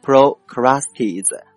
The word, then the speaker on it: Procrustes: OK，那么在希腊神话当中呢，也有这样一个人比伊登还有强迫症，据说呀他是一个残暴的强盗，他的中文名字呢叫做普罗克斯泰斯，英文呢读作 Procrustes，拼成 Procrustes 啊。